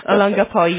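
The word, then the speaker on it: Olongapo: Alangapo, yeah. That's the O of the Philippines. It's not O, but the long O.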